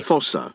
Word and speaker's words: Foster's: Fossa. – That's how you say it. That's the official pronunciation.